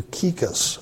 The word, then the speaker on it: Tychicus: Colossians 4:7, as to all my affairs, to Tychicus, our beloved brother, believe it or not, that's the way you pronounce it, and faithful servant and fellow bondservant in the Lord will bring you information.